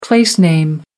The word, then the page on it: place name: American English